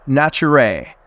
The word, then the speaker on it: Naturae: the co-founder and CEO of Naturae. Nicholas, it's wonderful to have you here today, brother. – Absolutely. Thank you for having me. And you nailed the pronunciation, which very often isn't the case.